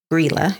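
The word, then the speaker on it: Grýla: Now, the other one I do want to touch base on is Grela or Grela. Grela is how she's pronounced.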